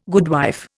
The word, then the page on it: goodwife: Indian